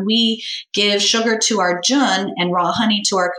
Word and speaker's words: Jun: and it's called Jun or June, J-U-N. We say Jun rhymes with fun, but you can call it June.